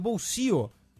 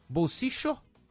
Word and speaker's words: bolsillo: O caçapo em espanhol, que se pronuncia bolsio, que tem as três pronúncias do duplo L lá na Argentina. – Isso. – Né? Bolsicho, como se fosse um CH, né?